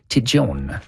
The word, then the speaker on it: Tête Jaune: Et là, pour avoir passé plusieurs vacances à Vailmount, qui est tout près de Tête Jaune, là-bas, on l'appelle Tijon. – Tijon. – Alors, la prononciation est un peu différente dans ce secteur-là.